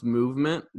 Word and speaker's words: MVMT: So I started looking into it, and the company MVMT, pronounced Movement, that sells like watches.